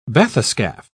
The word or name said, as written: bathyscaphe